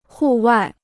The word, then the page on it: 户外: hù wài